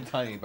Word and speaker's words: Tunney: Well, I want to say because I just started the fan club, Robin Tooney. – I've been Tunney, by the way. – Tunney. – You know how to pronounce the name right.